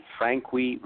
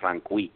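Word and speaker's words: Franqui: Frankie is spelled F-R-A-N as in Nancy, Q-U-I. So it's Harry Frankie.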